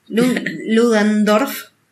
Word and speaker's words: Ludendorff: Porque acordate que el alemán superior, que tiene un nombre re difícil y no me a poder pronunciar, Lunderdorf. Ludendorff. Ludendorff. Ludendorff. Ah, no era tan difícil.